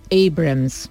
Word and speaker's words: Abrams: Estados Unidos 31 Abrams o Abrams que se pronuncia así.